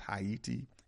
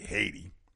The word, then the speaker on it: Ayiti: That's right, it's pronounced Haiti and spelled A-Y-I-T-I. But, of course, you might say Haiti.